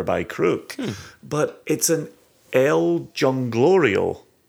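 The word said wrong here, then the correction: El Jolgorio: That's not how you pronounce the name, but we'll leave that in there. – How would you pronounce it? – I think it's El Holgorio.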